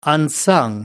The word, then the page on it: unsung: American English